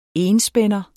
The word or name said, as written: enspænder